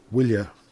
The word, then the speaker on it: bhuille: It's AR and then AN and the Woolya. There's no W in Irish, so the Woolya is spelled BHUILLE.